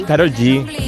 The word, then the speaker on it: Karol G: Esta es Karol G. – Karol G.